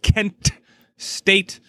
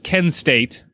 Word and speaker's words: Kent State: Kent, and listen to the broadcast, they really pronounce that Kent State. – Really make that T pop. – Yep, you don't want to confuse it with Ken State out there.